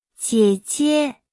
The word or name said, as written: 姐姐